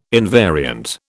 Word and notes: invariant: British English

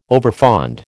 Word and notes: overfond: American English

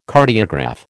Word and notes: Cardiograph: American English

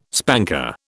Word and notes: spanker: British English

